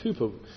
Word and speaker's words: Cowper: And I think of the hymn writer, William Cowper, as his name's spelt, but it should be pronounced Cooper, who with John Newton wrote so many beautiful hymns in the English language.